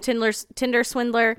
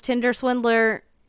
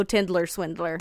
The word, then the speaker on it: Tinder Swindler: Like, it's a lot of Dutch, and so the way they pronounce Tinder Swindler varies and might make you giggle. They do, many times they go Tindler Swindler.